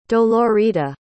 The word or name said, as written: dolorita